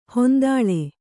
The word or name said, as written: ಹೊಂದಾಳೆ